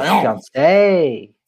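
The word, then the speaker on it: fiancé: The accent is on the final syllable. It's the fiance.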